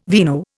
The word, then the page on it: vino: Indian